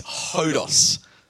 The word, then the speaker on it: hodos: The correct pronunciation of this word is hodos.